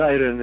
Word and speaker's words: Osirion: The Osirion in Abydos.